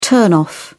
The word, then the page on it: turn off: British English